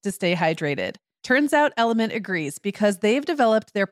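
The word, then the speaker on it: LMNT: Sarah, we're welcoming our sponsor, Element. That's spelled L-M-N-T, a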